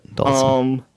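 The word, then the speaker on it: Dhalsim: How do you pronounce that? Dalsum? And Vega. Dalsum. Okay. Yeah. All right. So I was right. That's the way I always heard it. Dalsum.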